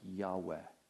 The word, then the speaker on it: Yahweh: Sometimes this word, people pronounce it as Jehovah. We're not exactly sure how to pronounce it because it's actually only four consonants in Hebrew. And we're not sure what the vowels are. But there's just four consonants. And modern-day scholarship, and I would tend to agree, says that really it was probably more pronounced something like Yahweh. Y-H-W-H, and they kind of figured out how to pronounce those with vowels.